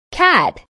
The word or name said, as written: cat